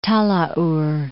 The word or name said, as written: Talaur Islands